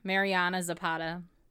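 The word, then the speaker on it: Mariana Zapata: Oh, I think I said her name wrong. It's Marianna Zapata.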